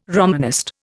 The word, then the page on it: Romanist: Indian